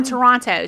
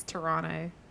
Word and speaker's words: Toronto: Well, she was from Toronto. So she was from the big city. – Oh, I know that one's wrong. It's Toronto. – Oh, Toronto. – You don't pronounce the T.